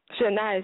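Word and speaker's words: Sinach: Shania is spelled S-I-N-A-C CH for somebody that might want to purchase that song.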